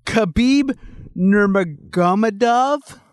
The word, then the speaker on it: Khabib Nurmagomedov: I'm gonna go ahead and say, Khabib Nurmagomedov versus Tony El Cucuy.